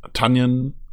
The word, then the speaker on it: Tonyan: Ähm, ja, äh, Tonyan, Tanyan spricht man ihn ja aus, Entschuldigung, ähm, mhm.